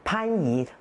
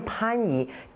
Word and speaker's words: 番禺: 还有我们有个地名很典型的，大家都很熟悉的番禺，为什么不能念番禺呢？它是念番禺，地名还有人名还有一些族名，它都要保留上古的读音，所以这个是非常古老的，念番禺不能念番禺，所以这个是第一点，这是从语音上来说保留了这个重唇音啊。